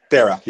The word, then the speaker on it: Dara: So you probably pronounce it Dara, right? Or no? Dara, yeah, that's Dara. Right. Dara, yeah, like Dara. Sarah.